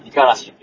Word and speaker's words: 五十嵐: 50 ストームって書いて、 50 嵐って書いて、あの、五十嵐って読むんですけど、濁らない。